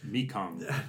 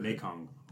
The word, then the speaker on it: Mekong: Mekong. – Thank you. – Mekong.